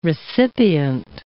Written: recipient

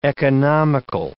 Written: economical

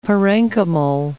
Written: parenchymal

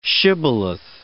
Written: shibboleth

